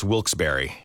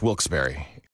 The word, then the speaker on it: Wilkes-Barre: And you're listening to Wilkes Bar Scranton's 97.9X. – Now, I had heard that it was supposed to be pronounced Wilkes-Barre. – Wilkes-Barre Scranton's 97.9X. The only way to pronounce Wilkes-Barre.